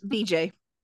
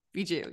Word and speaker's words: Vijay: Also, did you notice who else was in there who played Vijay or Vijay? That didn't sound right. Yes. – How do you say his name? – Vijay. – Vijay. Okay, that's what I thought.